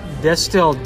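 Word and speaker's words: Destihl: Distill. All right, it's Destil.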